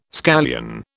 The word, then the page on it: scallion: British English